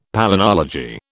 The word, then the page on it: palynology: British English